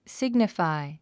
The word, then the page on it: signify: síɡnəfài; スィグニファイ